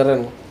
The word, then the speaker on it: Renoir: No, es Se escribe Renoir. y se pronuncia Renoir.